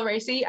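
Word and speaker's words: Raci: Paul Ricey. I guess that's how you say it, from Sound of Metal, and Lakeith Stanfield for Judas and the Black Messiah. – Yes, it's Paul Ricey.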